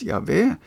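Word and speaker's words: Jahve: Noen mener at dette navnet skulle uttales Javé, men jødene sa bare Adonai som betyr Herren, eller noen ganger sa de ordet navnet.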